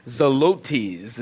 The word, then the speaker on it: Zelotes: Simon called the Zealot, or Simon Zelotes is how you pronounce it, actually.